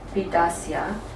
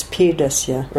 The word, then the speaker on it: pidasya: All right, and then Eva, tat kshanena eva upagata gata jivita eva shunya muki muka avalokini chandra pidasya pida. – It's pidasya, right? Long E then. Pida. Pida.